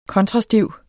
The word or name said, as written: kontrastiv